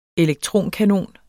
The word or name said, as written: elektronkanon